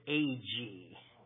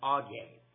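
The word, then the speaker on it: Agee: Now, you probably have pronounced it A-G. A-G-E-E, but it's pronounced Augee. And I like that better than A-G.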